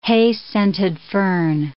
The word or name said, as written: hay-scented fern